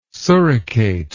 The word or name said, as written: suricate